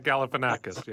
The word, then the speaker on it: Galifianakis: Zach Galifianakis, yes.